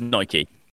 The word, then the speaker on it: Nike: It's Nike for reference. That's how the guy who runs the company says it is called. So that's how you say it.